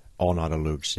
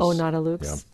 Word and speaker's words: O Nata Lux: Did I say that right in Latin? – O Nata Lux, yes. – O Nata Lux.